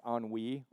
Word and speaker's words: ennui: It's E-N-N-U-I. You can just say it's pronounced ennui, or I would say it ennui because I say we a lot.